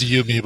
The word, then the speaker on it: Yumi: They have I think it's pronounced Yumi, by the way. – Oh, is it Yumi? All right. Not Yummy. I like Yummy.